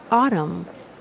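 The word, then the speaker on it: Audum: No, it's pronounced autumn, like the season.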